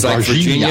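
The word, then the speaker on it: Varginha: I don't know if they ever recovered any wreckage from the Varinja. I can never pronounce that, the Brazil case. – Virginia. It's Virginia. Like Virginia, but you got to give a zh. It's Virginia with a zh.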